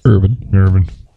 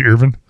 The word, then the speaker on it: Earvin: Irvin. It's spelled E-A-R-V-I-N. – Is it E-A-R? – Yep. – So it's Irvin? – Irvin. – Irvin. I just thought it was E-R.